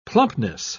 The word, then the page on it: plumpness: British English